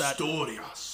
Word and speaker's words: Asturias: This cider is a collaboration with our friends at Sidra Riestra in, I'm not going to be able to pronounce Asturias. – That. Asturias, Spain.